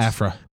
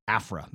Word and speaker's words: Aphra: I never say her name because I want to say Alpha, but it's not Alpha. It's Aphra. – Aphra, that's it.